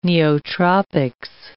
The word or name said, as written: neotropics